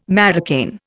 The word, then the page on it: marocain: Indian